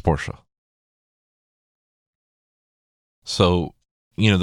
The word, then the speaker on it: Porsche: his Porsche, because it is pronounced Porsche. Don't don't argue with me. Don't argue with anybody because you'd be wrong.